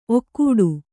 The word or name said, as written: ಔಕಾಟ